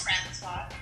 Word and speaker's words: Francois: It's Francois.